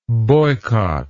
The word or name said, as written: boycotter